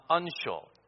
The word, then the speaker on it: uncial: The style of writing used was something called uncial. U-N-C-I-A-L. That's how it's pronounced.